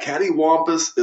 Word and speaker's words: cattywampus: But very good for a first effort, and you pronounced it correctly because a lot of people don't get cattywampus.